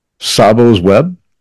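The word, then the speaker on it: Tsabo's Web: Sabo's Web, that's spelled T-S-A-B apostrophe S.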